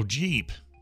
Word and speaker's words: Jeep: This is from Chris the Geep, G-E-E-P. – Oh, Chris the Jeep. – Oh, Jeep, not Geep.